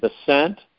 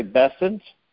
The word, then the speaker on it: Bessent: Number one, President Trump sent our new Treasury Secretary, Scott, I pronounce his name Bessent, some say Bessent, however you want to pronounce it, B-E-S-S-E-N-T.